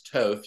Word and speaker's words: Toth: And that's not Thoth. He chose his own name. It's Toth. He's T-O-E-T-H is how it's pronounced.